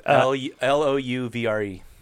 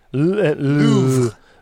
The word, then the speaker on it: Louvre: L O U V R E. – That's correct. – Yep. – Louvre.